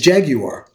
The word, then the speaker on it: Jaguar: You know, jag you are is the way the British pronounce what the car that we call Jaguar. – I didn't know that. – Yes. – Okay. – The British, the true British pronunciation for that is Jaguar.